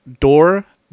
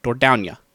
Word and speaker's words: Dordogne: I also Googled how to say the name, and unless Google is telling me incorrectly, it is Dordogne. Dordogne. – Dordogne.